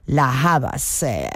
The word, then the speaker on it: La Havas: He tenido que ir a buscar cómo se pronuncia, porque se escribe su apellido literalmente así, o al menos el nombre artístico, La Havas. Y bueno, veo que es una adaptación fonética del apellido griego del padre de esta artista británica. Bueno, pues nada, no sabía cómo pronunciarlo, lo he ido a comprobar, y si es Liane La Havas, quien bueno, pues nos ofrece hoy esta versión del tema original de Radiohead.